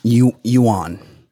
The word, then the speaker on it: Yuan: Yuan. – That's definitely Y-U-A-N.